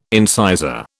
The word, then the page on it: incisor: British English